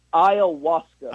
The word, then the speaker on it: ayahuasca: And ayahuasca? How do you say it? – Ayahuasca.